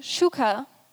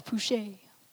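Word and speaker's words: psuche: It's pronounced shuka, but it's spelled P-S-U-C-H-E. I thought it was pouche, but that sounds more French.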